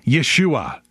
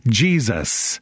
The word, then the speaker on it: Yeshua: First of all, he is Yeshua, or if you pronounce it in its anglicized form, Jesus.